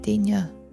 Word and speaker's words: denier: Man har dem jo typisk i forskellige denier, tror jeg, det udtales, hvis du ved, hvad det er. – Hvad er denier? – Det er denier.